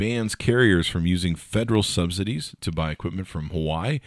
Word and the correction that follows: Huawei: which is actually pronounced... How do you pronounce Hawaii? Huawei. You pronounce it Huawei.